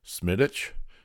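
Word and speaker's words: Smithwick's: I did Guinness and he did Smittich, and you gotta pronounce it without the W.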